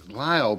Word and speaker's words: Lisle: So if you look at it, and it's Lyle, but it's spelled L-I-S-E. – Yeah, it's L-I-S-L-E. – Yeah. – L-I-S-L-E.